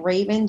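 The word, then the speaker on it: Raevyn: So my handle on Instagram is at Raven Jones. My name is spelled a little funky, so listen up. It's R-A-E-V-Y-N, and then Jones is normal, J-O-N-E-S.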